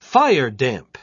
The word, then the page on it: firedamp: British English